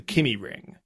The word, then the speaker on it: Kymi: Yeah, there is a grade one track in Finland called the Kimi Ring. It's not Kimi as in Kimi Räikkönen, it's spelled K-Y-M-I.